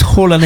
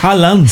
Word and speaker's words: Haaland: Oye, pero tú me decías que aquí el nombre de él es Erling Haaland, pero allá no es Haaland, es Haaland.